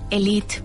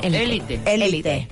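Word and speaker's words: Élite: En Worldwide normalmente le dicen Elite porque así se lee, pero como es español, de hecho tiene un acento en la E, y entonces pues decimos Elite.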